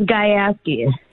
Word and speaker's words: Gajewski: Yes, it's Gajewski.